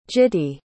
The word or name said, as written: gitty